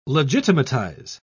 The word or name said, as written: legitimatize